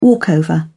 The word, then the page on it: walkover: British English